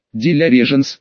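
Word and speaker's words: de la Régence: Дача нашего клиента название условное, то отправляюсь я обедать не куда-нибудь, а в Пале-Рояль, в кафе Регенства, Софи де ла Регенце по-французски, де ля Реженс, если по-русски произносить. Ну да,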